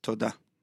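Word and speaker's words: Toda (תודה): Toda. – Toda.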